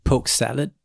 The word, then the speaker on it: Polk salad: Is the correct pronunciation poke salad? Like you got to say it almost like Dusty Rhodes would.